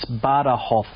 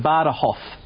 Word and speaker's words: Badajoz: I think it's a Spanish word, and it's Badajoz. – Oh, it's a soft J, is it? – Badajoz.